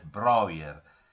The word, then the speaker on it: Breuer: A quel punto avvia una collaborazione con il medico e psichiatra austriaco Josef Breuer. Si pronuncia così, mi dicono,